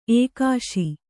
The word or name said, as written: ಎಕ್ಕಿಸು